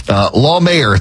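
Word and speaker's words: Lahmeyer: Law Mayor, thank you, Levi. For Law Mayor, Jackson Law Mayor. So there we go. Getting the pronunciation right. Jackson is, well, he's in second place now, right now. The way things are looking, we really got to put heavy emphasis on law in the first syllable of the last name, because maybe dealing with the divorce court here pretty soon, if not already.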